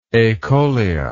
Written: acholia

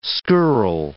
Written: skirl

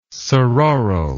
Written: sororal

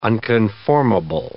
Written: unconformable

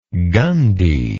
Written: gundi

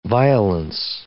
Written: violence

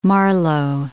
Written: Marlowe

